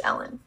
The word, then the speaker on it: Elin: It's spelled E-L-I-N. So, but I think it's pronounced Ellen. – E-L-I-N.